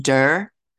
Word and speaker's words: duir: It's like dur. – D-U-I-R. – Yeah. D-U-I-R.